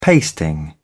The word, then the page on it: pasting: British English